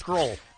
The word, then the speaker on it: Grohl: rhymes with roll. Yeah! Come on, play it. Let me hear it. – We did a game called Rhymes with Grohl.